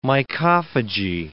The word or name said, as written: mycophagy